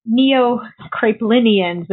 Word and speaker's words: neo-Kraepelinians: And what about the neo-Kraepelinians? Am I saying that right? – Yeah.